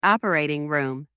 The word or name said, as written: operating room